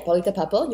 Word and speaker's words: Paulita Papel: So I'm, yeah, Paulita Papel, you pronounce it right.